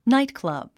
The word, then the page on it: nightclub: náitklʌ̀b; ナイトクラブ